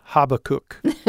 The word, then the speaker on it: Habakkuk: And the reason I wanted to get us back into that groove a little bit is because this time we come to Habakkuk. – Mm-hmm. I was in the UK once and I heard a pastor pronounce it Habakkuk.